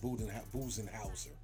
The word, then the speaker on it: Budenholzer: So you got to give all credit due to their coach, Mike Budenholzer.